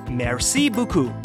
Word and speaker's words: Merci beaucoup: But it's actually pronounced Merci beaucoup. – Merci beaucoup.